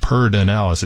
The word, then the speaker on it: Pedernales: We pronounce things kind of in a strange way sometimes here in Texas. It says, we pronounce it Pardinalis. It's spelled P-E-D-E-R. N-A-L-E-S, it's near Johnson City.